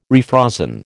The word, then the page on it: refrozen: American English